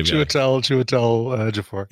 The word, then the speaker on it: Chiwetel Ejiofor: And the only real standout there was Cheadle Fond Ejiofor's performance, whatever his name is. I can't say his name right. It's 12 Years a Slave. – Chiwetel Ejiofor.